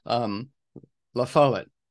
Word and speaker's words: La Follette: I keep pronouncing the right the name wrong. La Follette. That's right, Bob La Follette.